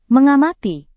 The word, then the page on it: mengamati: Indonesian